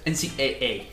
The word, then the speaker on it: NCAA: ¿Cómo lo pronunciamos en inglés? – NCAA. – NCAA. Exacto.